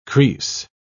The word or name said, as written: crease